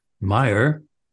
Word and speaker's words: Meyer: I could relate to that because when I first came to the US from Britain many years ago, some of my new American friends loved to call me Paul Meyer instead of Paul Meyer with the American. "Hello, Paul," they'd say. No malice or mockery, it's just fun. They hadn't mispronounced my name. Meyer is the right pronunciation by American English phonology rules, whereas Mayer would have been a mispronunciation.